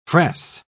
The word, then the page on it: fress: British English